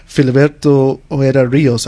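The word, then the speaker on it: Filiberto Ojeda Ríos: was the assassination several years ago of Filiberto Ojeda Rios.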